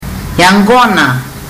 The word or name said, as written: yaqona